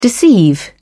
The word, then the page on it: deceive: British English